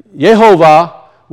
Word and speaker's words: Yehovah: It was pronounced Jehovah, but there was no J sound, so the J just kind of magically appeared. But we know that Yehovah would have been his pronunciation before all of that took place.